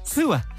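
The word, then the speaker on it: Poo-Ah: So it's spelled P-O-O hyphen A-H. – Yes. – Pua. Around the town of Orleans over the weekend. So there's obviously an Orleans and a New Orleans. Mm-hmm. The Orleans Police Department posted online to say Pua had ambushed his pen.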